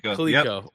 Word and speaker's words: Coleco: Was it Coleco? Is that Coleco.